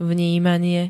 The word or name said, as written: vnímanie